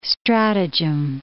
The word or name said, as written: stratagem